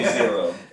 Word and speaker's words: Xero: I still can't pronounce it. I'm sorry. – You call me Xero.